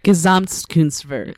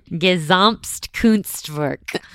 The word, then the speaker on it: Gesamtkunstwerk: Gesamtkunstwerk. – Perfect. Gesamtkunstwerk.